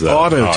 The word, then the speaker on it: autotomy: We're going to talk about the strange world of autotomy. – Mm-hmm. – Am I pronouncing that right? – Autotomy is autotomy. – How I'm pronouncing it.